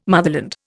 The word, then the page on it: motherland: Indian